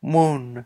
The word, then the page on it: mun: Lwaitel